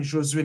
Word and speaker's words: Josue: After years of contemplating within me, like being Josh in one space and being Josue in another space,